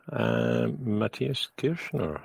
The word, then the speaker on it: Kirschner: So tonight we have a very special guest and friend of the show, Matthias Kirchner, if I pronounced that correctly.